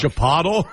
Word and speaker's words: Chipotle: C-H-I-P-O-T-L-E. – C-H-I, Jesus, slow down. C-H-I what? – P-O-T-L-E. – Chipotle. – Chipotle is the correct pronunciation.